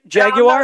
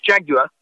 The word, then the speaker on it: Jaguar: Did I say it right? Jaguar? – No, that's really, you said it wrong. It's Jaguar.